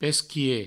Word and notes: pesquier: Provençal; pesquie

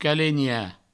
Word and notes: calenhar: provençal; calegna

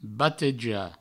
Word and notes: batejar: Provençal; bateja

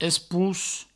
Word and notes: esposc: provençal; espousc

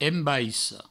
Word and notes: embaissa: embaisso; Provençal

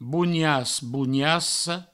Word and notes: bonias, boniassa: bounias, bouniasso

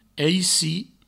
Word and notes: aici: eici

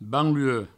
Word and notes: banlue: Provençal

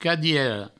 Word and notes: cadiera: cadiero

